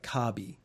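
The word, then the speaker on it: Kabi (カービィ): You have a hoodie and it's got Kirby's face in the front and it says Kirby across the top, except the Japanese pronunciation of Kirby is a lot more like Kabi. And Kabi, if you were going to spell it in English, you would put K-A-R-B-Y, or maybe a double I or something like that.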